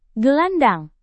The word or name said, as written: gelandang